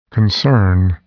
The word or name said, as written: concern